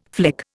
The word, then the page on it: flick: Indian